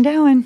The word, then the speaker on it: Dowen: Allison Doan or Allison Down? Wait, let me guess. Allison Doan. Allison Dowen. – It's Allison Dowen.